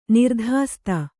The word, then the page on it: ನಿರ್ಧಾಸ್ತ: nirdhāsta